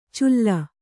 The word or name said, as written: ಚುಲ್ಲ